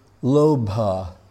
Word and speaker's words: lobha: But when I got to the end, it said, from the Sanskrit word lobha, L-O-B-H-A, which we know very often, they don't pronounce the ha. So it would be lobh, which is very similar to love, right?